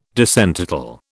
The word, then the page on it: disentitle: British English